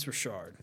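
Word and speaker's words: Rachaad: First of all, pronounce his name. Rashard. – Rashard. – Rashard. – I'm saying Rashard for the rest of the year. It's Rashard. There's a C in there.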